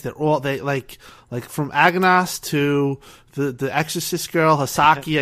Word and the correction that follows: Hisako: They say Hisako. It's Hisako.